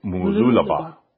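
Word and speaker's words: Mooloolaba: Because we were always saying Mululaba. And it's Mululaba. And it's like, I mean, it's still hard to pronounce.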